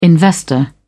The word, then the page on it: investor: British English